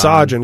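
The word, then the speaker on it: Saajan: It's Sajin, because I remember when they were singing Sajin. The song, I remember thinking, are they saying Sergeant? – Sajin. – So I feel like they kind of pronounce it Sajin.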